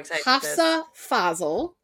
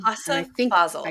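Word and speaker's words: Hafsah Faizal: So next week we are reading A Tempest of Tea by Hafsa Fazel. I did look up how to say her name. – Hafsa Fazel.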